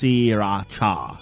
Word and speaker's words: Sriracha: Well, it's supposed to be pronounced Sriracha, according to the movie.